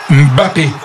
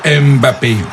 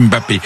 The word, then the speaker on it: Mbappé: Alors, vous avez vu comment Elvin, il prononce bien, il dit bien Mbappé, et non pas Mbappé. Dans la langue, on dit Mbappé.